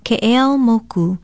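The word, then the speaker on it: Ke'eau Moku (Keʻeaumoku): Aloha, ovau oleleni poli ahu, and our Hawaiian word of the day is another frequently mispronounced Hawaiian place name, Ke'eau Moku. That well-driven street was probably named for a governor of Maui who bore the same name as his father, an ally and father-in-law of Kamehameha I. It is spelled K-E-Okina-E-A-U-M-O-K-U.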